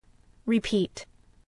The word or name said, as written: repeat